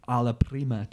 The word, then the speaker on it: alla prima: He learned the alla prima technique.